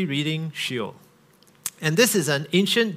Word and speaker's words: Sheol: So the first word, in fact, in the word cloud, was Sheol. And that's pronounced she-ol, Sheol.